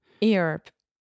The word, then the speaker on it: Europe: Can we focus one second on the pronunciation of the word Europe? – Europe. – Yeah. So just think about your up.